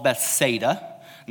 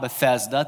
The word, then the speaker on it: Bethsaida: I found out it's called Bethsaida, not Bethesda. That's a different place. My girlfriend was in Israel recently. She told me I was saying it wrong, so I'm trying to say it right. It's Bethsaida. Everyone say Bethsaida. Bethsaida, okay? It's different than Bethesda. Here's what you need to know about Bethsaida.